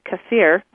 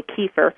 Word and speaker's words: kefir: And they found that over the years, these people who drank this milk kefir is the correct way to say it. In America, we say kefir.